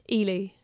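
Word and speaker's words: Ehle: How the fuck do you pronounce her last name? – Ely. – Ely. – Yeah, Jennifer Ely. – I was saying Eel for years.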